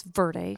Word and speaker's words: Verde: I do know it's Verde because that's how you say it in Spanish. – Well, it is. – Verde. Do you have to roll the R? – Of course.